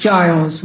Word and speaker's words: Giles: It's Giles with a G. It's a G-I-L-E-S, Giles.